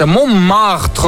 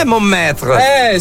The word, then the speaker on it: Montmartre: Semplice da spiegare cosa succede appunto a Montmartre, dove una serie di Montmartre, perché mi devi modificare la pronuncia? Montmartre. – Ah, non è Montmartre.